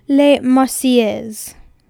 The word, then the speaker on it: La Marseillaise: Hi, it's a year later, here to correct my pronunciation. It's Les Marseillaises.